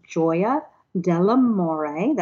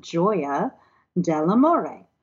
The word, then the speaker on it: Gioia dell'amore: So our name is the joy of love in Italian, and it's pronounced Gioia dell'amore. That first word gets everyone, but it's simply the English word joy with an A on the end, Gioia dell'amore.